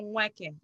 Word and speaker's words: Nweke: You you, you swallow the N, so it's Ifunanya Nweke.